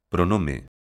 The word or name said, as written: pronome